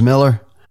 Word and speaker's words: Moeller: Back in the top 10 this week, Gary James Miller, that's spelled M-O-E-L-L-E-R. He has a song called Coastal Girl. Welcome, Gary James Miller, number 8 in the Trop 40.